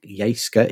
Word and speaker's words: Jyske: I think Jyske is the correct pronunciation. – It is. Not, I have Danish cousins. That does sound about right.